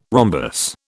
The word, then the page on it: rhombus: British English